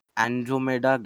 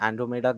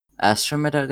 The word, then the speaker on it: Andromeda: It's the Andromeda Galaxy. Do you know about the Andromeda Galaxy? – Astromeda Galaxy? – Andromeda.